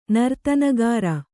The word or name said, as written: ನರ್ತನಗಾಱ